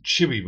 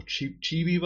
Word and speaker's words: Chibi: The first one that we're looking at is Chibi Ball. Or Chibi Ball? How do you pronounce that? – Chibi. Chibi. Chibi. That's how you say it.